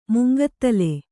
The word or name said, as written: ಮುಂಗೂದಲು